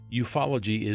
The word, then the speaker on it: ufology: You know, the other day when I was doing one of our episodes, I was confused by a word that I saw, ufology, U-F-O-L-O-G-Y, and it is pronounced ufology.